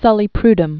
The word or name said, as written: Rene Francois Armand Sully-Prudhomme